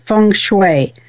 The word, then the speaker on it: feng shui: Am I pronouncing that correctly? – Yes, you are pronouncing it correctly. Actually, there are many dialects in saying feng shui, and so this dialect is kind of more from the Mandarin Chinese dialect, which is the most used dialect or acknowledged in China.